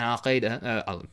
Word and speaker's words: Al-Qaeda: In English you say Al Qaeda, which makes no sense. In Arabic it's Al Qaeda, which means the base.